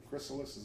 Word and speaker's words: chrysalis: It is next transformed into a chrysalis, or chrysalis, is that how you pronounce it? – Yeah.